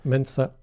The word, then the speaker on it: Mensa: Mensa.